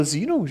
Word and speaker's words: xeno: Tak já vyslo, xeno se vyslovuje zínou, že jo, v Americe.